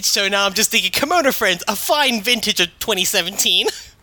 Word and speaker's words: Kemono Friends: God, is that how you say it? All right, it's Kemono Friends, right? – God, Kemono Friends.